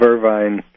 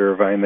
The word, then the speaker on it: Irvine: So, you know, we're still in the process of moving in, but it's probably entirely the opposite of Irvine, California. In fact, they don't even pronounce it Irvine. They pronounce it Irvin.